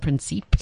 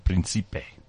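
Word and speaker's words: Príncipe: The others where visas have been waived are for Ghana, Cuba, and I never know how to pronounce it, Sao Tome and Principe. – Sao Tome and Principe. – Principe. – Oh, Principe.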